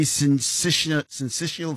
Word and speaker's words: syncytial: Am I pronouncing the name of this virus properly? Respiratory syncytial virus? – Firstly, good evening and thank you for having me. You're actually, yeah, that's actually how you pronounce it.